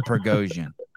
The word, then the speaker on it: Prigozhin: Prigozhin. – I don't appreciate that. Go on. – Yevgeny Prigozhin.